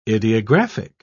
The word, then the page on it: ideographic: British English